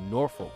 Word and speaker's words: Norfolk: So the one of the neighboring towns I grew up in is Norfolk, Virginia. But there are a lot of pronunciations of Norfolk. People say Norfolk, Norfolk. Norfolk, Norfolk. You don't hear any O's in the Norfolk.